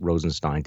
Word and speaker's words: Rosenstein: Deputy Attorney General Rod Rosenstein, I'm going to pronounce it Rosenstein today, although he says you can go either way on this, apparently, was at the museum yesterday.